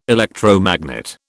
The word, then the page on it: electromagnet: British English